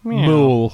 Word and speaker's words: Mewl: Mewl. – Mewl. – Mewl. – Mewl. – M-E-W-L.